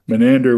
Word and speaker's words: Menander: Tell me about, is it Menander? Am I pronouncing that correctly? He came from a later period, and I guess the Byzantines didn't consider him as important to put on the vellum. – That's right.